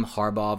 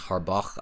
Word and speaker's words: Harbaugh: It's just like, it's not as bad as my wide receiver core corpse situation that I got going on, but Jim Harbaugh versus Harbaugh, I always, for some reason, pronounce that G-H,